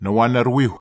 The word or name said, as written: nowanarwiu